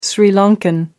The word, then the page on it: Sri Lankan: American English